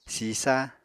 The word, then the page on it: sisa: Apatani